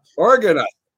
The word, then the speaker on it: Argonauts: Argonauts. Argonauts.